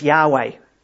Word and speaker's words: Yahweh: The first, in the capital LORD, is actually the name that was given throughout the Old Testament, and it's YHWH. Yeah, most of the time we pronounce it as Yahweh.